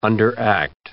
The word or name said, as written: underact